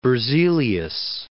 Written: Berzelius

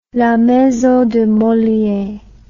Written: maison de moliere la

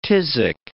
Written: phthisic

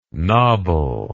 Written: knobble

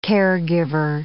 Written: caregiver

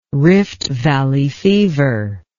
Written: Rift Valley fever

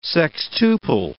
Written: sextuple

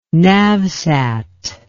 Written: navsat

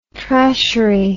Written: trashery

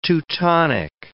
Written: Teutonic

